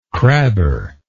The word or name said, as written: crabber